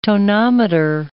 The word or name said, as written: tonometer